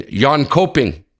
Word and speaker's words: Jönköping: And the World Juniors Championship gets underway Sunday, May 15th in Jönköping, Sweden.